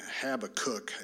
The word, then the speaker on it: Habakkuk: Yeah, Habakkuk, I think, is the correct pronunciation.